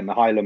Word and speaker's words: Mykhailo: Yeah, Mykhailo Mudryk. So in terms of, just forget about the K in his first name, and Mudryk's perfect.